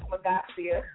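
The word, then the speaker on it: Magassia: Google me because I'm the only one with that name, Magasia.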